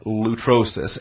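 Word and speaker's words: lutrosis (λύτρωσις): And the noun lutrosis, L-U-T-R-O-S-I-S, lutrosis. Feminine singular accusative noun, lutrosis, L-U-T-R-O, that's the long O, the omega, looks like a W, so it's the long O omega, L-U-T-R-O-S-I-S, number 3085, lutrosis, placing the accent on the first syllable, lutrosis, L-U-T-R-O-S-I-S, number 3085, ransoming, releasing, redemption, referring to the purchase price in order to redeem a slave.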